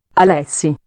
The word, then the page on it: alessi: prononciation italienne